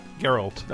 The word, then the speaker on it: Geralt: Is it Geralt? – Geralt. – I said it right.